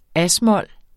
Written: as-mol

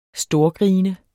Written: storgrine

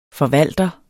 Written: forvalter

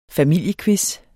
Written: familiequiz

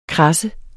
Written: KRADSET

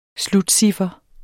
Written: slutciffer

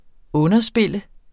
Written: underspil

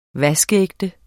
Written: vaskeægte

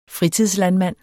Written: fritidslandmand

